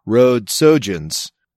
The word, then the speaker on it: Rhodesogens: The proper pronunciation is, and then he phonetically spells it out, Rhodesogens.